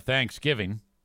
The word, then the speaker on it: Thanksgiving: The emphasis is on the second syllable, Thanksgiving, not Thanksgiving.